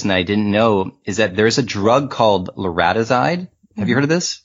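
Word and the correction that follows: larazotide: Lorazitide. That's the right pronunciation.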